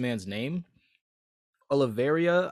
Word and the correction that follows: Oliveira: I think it's Oliveria or Oliveria. I'm not Spanish, so I would not have pronounced it. Mark might know the last name. – Oliveira, yeah.